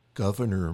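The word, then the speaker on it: Gouverneur: His name was Governor, and it's spelled a little differently from that, but we only know how to pronounce it, actually, because John Adams' wife, Abigail, spelled it phonetically, and so we know how to pronounce it.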